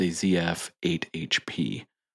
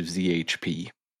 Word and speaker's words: ZF 8HP: That transmission thing, by the way, just because I have to say it, because I butchered it, it's a ZF 8HP. – 8HP, yeah. – That's right. – I combined that into ZHP.